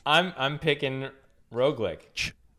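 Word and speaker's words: Roglič: Roglic.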